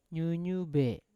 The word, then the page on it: nyunyube: Yawa